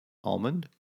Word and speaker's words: almond: Almond? – No. – Almond? – No. It's almond. The L is silent.